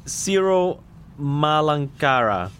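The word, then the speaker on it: Syro-Malankara: So if her father was Ciro Marancalaba, I might have said it wrong. I'm sorry. It's Ciro Malankara. – Yeah, that's very good pronunciation.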